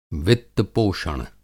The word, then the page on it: वित्त पोषण: Hindi